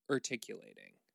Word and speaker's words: urticating: It's spelled U-R-T-I-C-A-T-I-N-G. Articulating.